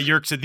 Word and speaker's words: Yerxa: Ron Yerxa, yeah. – Yeah, Yerxa.